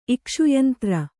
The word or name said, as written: ಇಕ್ಷುಯಂತ್ರ